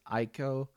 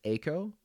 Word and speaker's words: Eiko: E-I, Steiner, okay? There's also another character, E-I-K-O. Is that Steiner? Is that Iko? Then you keep the same EI pronunciation.